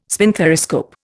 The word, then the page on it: spinthariscope: Indian